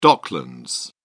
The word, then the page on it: docklands: British English